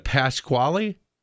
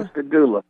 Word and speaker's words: Pascagoula: And I can't pronounce that river, but Pasquale River? – Pascagoula.